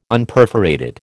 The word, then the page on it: Unperforated: American English